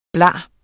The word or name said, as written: bla